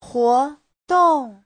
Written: huo2dong4

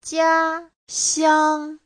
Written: jia1xiang1

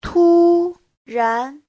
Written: tu1ran2

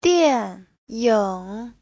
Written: dian4ying3

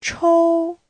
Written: chou1

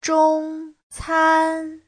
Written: zhong1can1

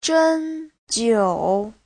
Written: zhen1jiu3

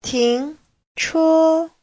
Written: ting2che1

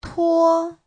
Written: tuo1

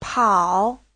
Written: pao3

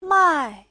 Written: mai4

